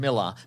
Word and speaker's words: Millar: I know it is pronounced Miller, but we've said Millar too many times to go back now.